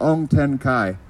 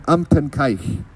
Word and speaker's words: Umtenkaich: So the name of Top Street, as pronounced currently, is pronounced like Ong Ten Kai. But in fact, it comes from the Nuchok word Umtenkaich.